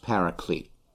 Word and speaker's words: paraclete: The word that's used here is the one Greek term parakletos, which in English would be pronounced paraclete.